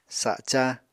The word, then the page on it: saca: Apatani